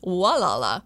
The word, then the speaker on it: Gualala: Anthony wrote in to say that my pronouncement of Gualala was the most popular one, but originally it's pronounced Walala, which he says is Indian for where the river meets the ocean, or maybe it's where the G is a W.